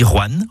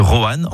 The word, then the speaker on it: Roanne: Vous avez remarqué comment je dis Roanne en une syllabe plutôt que Roanne en deux syllabes. C'est comme ça qu'on y dit dans la région.